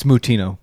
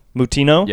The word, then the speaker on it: Moutinho: Sticking on to the UFC stuff, Chris, I'm probably pronouncing this wrong too. Chris Moutinho. – It's Moutinho. – Moutinho. – Yeah. – Yeah. – Because it's kind of a Portuguese name, isn't it? – I have no idea.